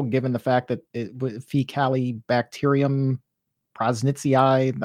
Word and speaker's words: prausnitzii: prausnitzii, am I pronouncing that correctly? – Prausnitzii, yeah. – It's prausnitzii. – P-R-A-U-S-N-I-T-Z-I-I.